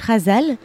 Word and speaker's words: Ghazal: Oui, alors c'est un mot en arabe et on le prononce avec un R, mais ça s'écrit G-H, Razal.